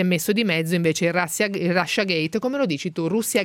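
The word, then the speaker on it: Russiagate: Comunque effettivamente credo che la pronuncia più usata sia Russia Gate. – Infatti all'inglese.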